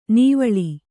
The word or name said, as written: ನಿವ್ವಳ